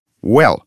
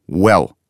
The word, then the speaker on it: well: А английский звук «в» произносится одними губами, как будто лягушка квакает. «В», «в». Попробуй. – «В», «в». – «Well». А вот, кстати говоря, и словечко с этим звуком. «Well».